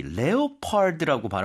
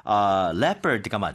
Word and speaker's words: leopard: L-E-O라고 시작을 하니까 많은 분들이 레오파르드라고 발음하시는 분들이 있더라고요. – 아. – 근데 그게 leopard가 맞죠.